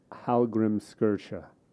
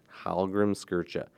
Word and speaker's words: Hallgrímskirkja: Hallgrímskirkja. – Hallgrímskirkja.